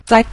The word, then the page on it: zai: zai5